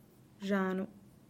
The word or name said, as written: zhanu